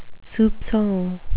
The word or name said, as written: soupcon